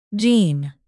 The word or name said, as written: gene